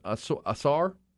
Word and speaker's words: Ausar: Well, this will mark the first time when the Thompson brothers, Amen and Asar, is how you say it, A-U-S-A-R, are projected to go maybe back-to-back, both in the top five.